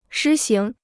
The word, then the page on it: 施行: shī xíng